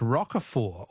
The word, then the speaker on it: Roquefort: The French Roquefort, also with a silent T, with a silent T, is very smelly.